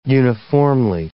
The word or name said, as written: uniformly